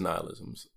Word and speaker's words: nihilism: I wanted to make sure I get it right, and I went to, to search it on Google, did a pronunciation, and it is nihilism.